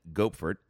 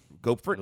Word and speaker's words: Gopfert: You actually are pronouncing it right, spot on. – Gopfert? – I looked it up. The wow. – Phonetics. – Okay. Gopfert, he's plotted out the returns and the trend, which has been over the, he looked at it the last 3 trading days.